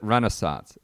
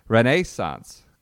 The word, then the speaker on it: Renaissance: They do not pronounce it Renaissance. It's Renaissance Club uh, this week.